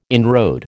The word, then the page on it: inroad: American English